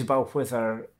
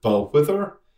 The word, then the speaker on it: Balquhidder: Balquhithir is spelled as if it would be Balquhidder, and indeed there are versions of it sung where it's pronounced, as you say, Balquhidder, Balquhithir.